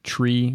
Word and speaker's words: trie: And the very confusing tree, which is spelled T-R-I-E.